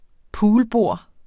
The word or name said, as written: poolbord